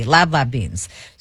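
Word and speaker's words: lablab beans: Yes, just like it's spelled just like it sounds. L-A-B-L-A-B, lab beans.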